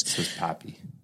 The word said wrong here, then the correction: papal: Papal, that's how you pronounce it.